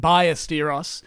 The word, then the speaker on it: Ballesteros: As Australians would say, Ballesteros, for the correct pronunciation, Ballesteros.